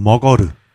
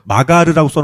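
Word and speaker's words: Magar: 네, 그래서 마가르라고 써놓고 머거르라고 읽어야 네팔식 발음입니다.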